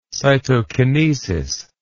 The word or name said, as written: cytokinesis